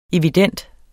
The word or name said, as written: evident